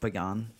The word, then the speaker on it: pagan: They started off as as as uh, pagan things. Christmas trees. Uh, it's actually pronounced pagan. Pagan.